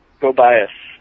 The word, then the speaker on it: Gobias: Your team is, I believe, Jobias Industries? – Gobias, as in go buy us some coffee from Arrested Development. – You say, wait, it's pronounced Gobias?